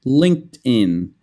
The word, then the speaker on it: LinkedIn: It's not LinkedIn or something like that, the pronunciation. LinkedIn. LinkedIn. And it kind of sounds like din, so move the D.